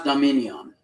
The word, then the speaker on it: Dominion: It's called Demedian. Did I pronounce it right? – Let me look at this. It almost sounds Dominion. It's pronounced Dominion.